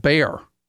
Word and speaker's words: Behr: All from a company called Bear, B-E-H-R.